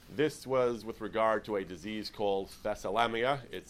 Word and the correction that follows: thalassemia: I'm not sure I'm pronouncing that right, but it's a disease. – Thalassemia. – I'm sorry, thank you. Thalassemia, it is a disease by which that affects the blood, that the cells are not, the blood cells are improperly constructed.